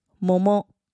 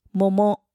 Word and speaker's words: もも: 接下来是桃子，もも，もも，もも。